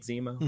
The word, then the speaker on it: Zemo: And Zemo, I'm saying his name right, Zemo.